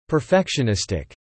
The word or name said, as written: perfectionistic